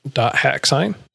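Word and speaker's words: .hack//SIGN: Do you remember when you played, I'm going to mispronounce this because I always do, hack.sign? Or is it sign.hack? – Dot hack sign? – Doc hex.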